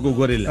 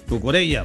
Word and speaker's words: Cucurella: No, es Cucurella, no Cucurella. – Claro. – Es Cucurella. Cucurella, sí.